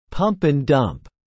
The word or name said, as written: pump and dump